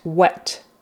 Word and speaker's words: whet: Does smelling that spaghetti whet your appetite? Wet. It's the same as wet. The sidewalk is wet because of the rain. Wet, wet. Same pronunciation.